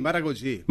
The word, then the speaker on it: Maragogi: ¿Maragogi? – Sí, Maragogi.